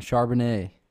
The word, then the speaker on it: Charbonnet: And that's what we landed on, Charbonnet.